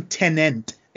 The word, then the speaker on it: Tenet: So Tenet is the I keep saying Tenet, and there's no second N in there. I gotta pronounce it right.